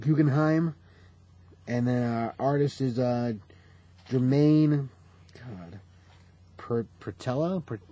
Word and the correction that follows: Peralta: I'm looking at you for pronunciation. – I haven't read the name. Let me see. – Prala? – Peralta. – Peralta, there you go.